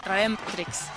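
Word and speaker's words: Redemptrix: Vamos a pronunciarlo bien. Es así, ¿no? Redemtrix, ¿es así? – Sí, la acentuación es Redemtrix.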